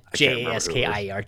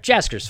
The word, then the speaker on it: Jaskier: But yeah, it's just Jaskier. Jasker's fine. You can have a silent letter, whatever.